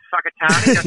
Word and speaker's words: Whakatane: It's Whakatane. Yep. And it's actually spelt with a WH. It's very weird.